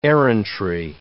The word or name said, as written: errantry